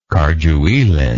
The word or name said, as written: cardueline